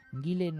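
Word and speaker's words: Gielen: Gillen, no obstante, es capaz también de dirigir, por supuesto, todo el repertorio.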